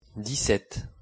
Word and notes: dix-sept: French

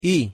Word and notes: y: Spanish

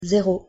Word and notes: zero: French

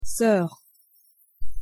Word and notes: soeur: French

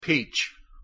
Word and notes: peach: English

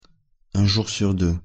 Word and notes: un jour sur deux: French

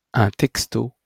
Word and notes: un texto: French

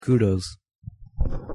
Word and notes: kudos: English

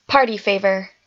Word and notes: party favor: English

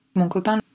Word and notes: mon copain: French

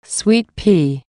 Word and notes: sweet pea: English

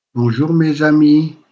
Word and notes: bonjour mes amies: French

